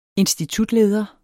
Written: institutleder

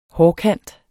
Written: hårkant

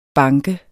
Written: banke

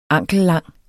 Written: ankellang

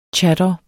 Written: chador

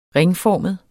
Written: ringformet

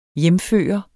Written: hjemføre

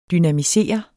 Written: dynamisere

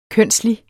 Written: kønslig

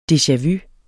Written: deja-vu

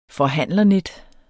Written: forhandlernet

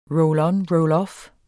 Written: roll-on-roll-off

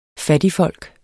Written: fattigfolk

